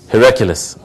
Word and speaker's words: Heraclius: Heraclius.